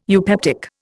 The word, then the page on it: eupeptic: Indian